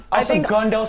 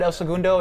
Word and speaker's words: El Segundo: El Segundo, some pronounce it that way, but El Segundo is the way us locals call it.